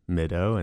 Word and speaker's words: Mideau: Well, the band name is called Mido, and that's spelled mid, E-A-U, like the French word for water, M-I-D-E-A-U.